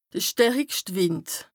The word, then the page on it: de stärikscht Wìnd: Bas-Rhin; Strasbourg